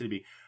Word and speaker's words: Sidibe: Also, just the fact that they had Gabrielle Sidibe, yeah, that's how it's pronounced, Sidibe, from the movie Precious appearing as one of the characters.